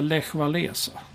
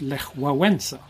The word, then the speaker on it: Lech Wałęsa: Han hette ju jättelänge Lech Walesa, sa man. Lech. Sen är det plötsligt kommer nej, från och med nu ska han uttalas Lech Wałęsa. – Jag kommer mycket väl ihåg det. – För så sa man i Polen, och då kommer man säga, men vi är ju inte i Polen, kunde jag då liksom lite uppköp säga på redaktion.